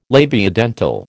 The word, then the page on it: labiodental: American English